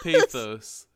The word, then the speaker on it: pathos: Not every animated movie needs to be overflowing with pesos. Sometimes pesos? The form of currency in Mexico? – I tried to say it correctly. I know what the word was. Pathos.